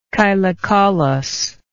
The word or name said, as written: chylocaulously